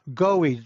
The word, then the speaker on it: Goewey: Goewey, just like go in an E.